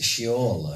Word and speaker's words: Sheol: but it is Sheol, and it is spelled S-H-E-O-L.